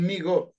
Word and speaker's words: Mi-Go: Sí, son Migo, M-I-G-O, los Migo.